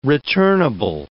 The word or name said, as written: returnable